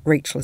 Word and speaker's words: Rachel: In fact, in French, Rachel is pronounced Rachel.